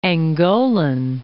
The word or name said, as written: Angolan